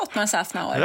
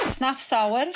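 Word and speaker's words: Szafnauer: Snaffsauer? Vad sa du? – Jag sa ju Ottmar Snaffsauer. – Ja, exakt så. – Snaffsauer.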